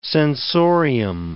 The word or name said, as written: sensorium